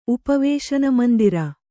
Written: ಉಪವೇಶನಮಂದಿರ

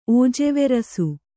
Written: ಓಜೆವೆರಸು

